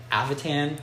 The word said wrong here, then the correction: Ativan: I don't know if I'm saying that right. – Ativan. – Ativan? – Yeah.